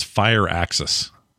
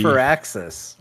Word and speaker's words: Firaxis: Somebody was arguing with me how you say it. Well, you guys say for Axis, right? – Yeah. – Somebody told me it's Fire Axis. – I think it's for Axis.